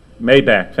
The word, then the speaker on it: Maybach: There's a car, Maybrook, am I pronouncing it right? Yeah? Maybach, thank you.